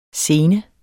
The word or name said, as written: scene